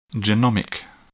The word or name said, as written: genomic